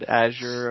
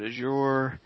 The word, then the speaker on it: Azure: How do you pronounce this word, A-Z-U-R-E? – Yeah, it's a good question. I hear people pronounce it in many different ways. I've heard Azure, I've heard Azure, many different flavors in between. And from my going to Microsoft conferences and going to people in the know from this space, the premise and what I hear is the pronunciation of Azure. So that's the way I pronounce it.